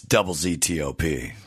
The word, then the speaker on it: ZZ Top: So if you want to say it like their classic rock DJs could do, it's double ZTOP.